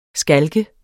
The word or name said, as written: skalk